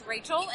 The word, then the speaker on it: Raychul: They can see me all over the place, but my name is Rachel, and it's spelt weird. It's R-A-Y-C-H-U-L,